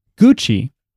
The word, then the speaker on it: Gucci: Is spelled G-U-C-C-I because in Italian, Miles knows this. – That's right. – A C, I'll let you say it. – Followed by an E or an I is pronounced Che.